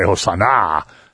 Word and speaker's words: Hosanna: And when you say in Hebrew, when you put a big accent on the last word, on the last letter of the word, and say Hosanna, that means like save us now.